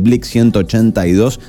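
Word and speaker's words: Blink-182: Porque si vamos a tener parte de Blink 182, así se pronuncia esta banda, nada de decirlo en inglés.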